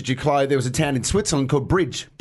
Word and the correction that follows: Brig: B-R-I-G. – Yep. – So we wanted to send someone to Bridge and Limo. To go there first, then go to Limo. – I think that's pronounced Brig.